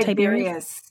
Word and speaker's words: Tiberius: And I know, is it Tiberius? Is that how you say it? – Tiberius, yes.